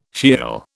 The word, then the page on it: Sheol: British English